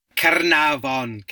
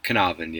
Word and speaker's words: Caernarfon: Caernarfon Castle is, he put some effort into the Welsh pronunciation, but Caernarfon, yeah.